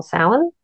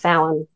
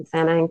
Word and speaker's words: Samhain: The Gaelic way of pronouncing it's more Samhain. – Oh, okay. – So it's Samhain rather than Samhain, because their words are never pronounced how they look.